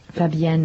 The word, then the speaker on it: Fabienne: Fabienne Marsh. – Fabienne. – Fabienne. – Fabienne. It's French. – Yes. It's sort of like Do-Re-Mi, Fa, and then Japanese.